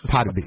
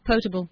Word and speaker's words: potable: Potaby. It wasn't portable. It was potaby. – What was it? Was it portable? – Wannabe? – Potable.